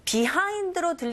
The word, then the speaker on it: behind: 혀가 어디에도 닿지 않게끔 하시고, behind로 들리는 경우도 있지만, 빈번하게 원어민의 입에서 나오는 소리는 behind, B라고 안 들리고, 그냥 아주 간략하게 behind. 이렇게 뒷부분에 강세 줘서 발음한다라는 거.